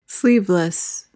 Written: sleeveless

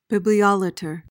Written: bibliolater

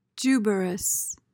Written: juberous

